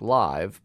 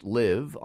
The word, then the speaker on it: Live: Looks like Live, but it's pronounced Live